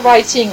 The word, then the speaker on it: writing: writing, on ne prononce pas le W, donc c'est comme si ça commençait par un R,